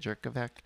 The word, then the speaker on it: Jurkovec: Well, and I did make a note that the Notre Dame quarterback, Phil Jurkovec, I think is how you say it. – Jurkovec.